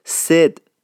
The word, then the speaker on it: سید: مثلا کلمه سید به صورتی سد تلفظ میشه، مثلا مثال میزنم بعدون، سید محمد میشه سد محمد، یا سید علی میشه سد علی.